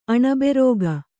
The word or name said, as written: ಅಣಬೆರೋಗ